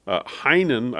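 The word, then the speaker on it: Heien: Supreme Court decision, Heinen, I believe is the proper pronunciation, v.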